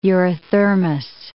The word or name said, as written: eurythermous